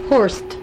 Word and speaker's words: Horst: Horst. – Horst. – H-O-R-S-T. – H-O-R-S-T, Horst.